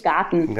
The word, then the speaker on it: Garten: It's Garten.